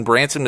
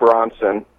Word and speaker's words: Branson: And we mentioned in a segment when we were talking about we should do the next bypass in Branson, Missouri, and this is what he had to say about that. – Also, it's Branson, Missouri, not Bronson. Don't pronounce it like that, because that's weird. It's Branson, and yes, let's drive down there.